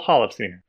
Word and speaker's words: Holofcener: With a screenplay by Nicole Holofcener, Ben Affleck, and Matt Damon.